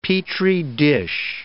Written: petri dish

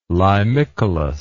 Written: limicolous

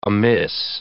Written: amiss

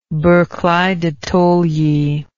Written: barclay de tolly

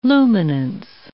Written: luminance

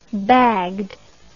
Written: bagged

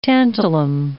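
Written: tantalus monkey

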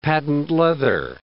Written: patent leather

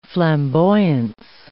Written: flamboyance

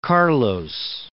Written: Carlos

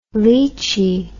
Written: lichi

